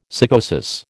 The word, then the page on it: sycosis: American English